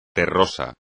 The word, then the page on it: terroso: espagnol